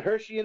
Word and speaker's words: Herschui: It's the Hershey and the Keeg Show. – Yeah. And Hershey is spelled with an S-C-H. – Yep. H-E-R-S-C-H-U-I.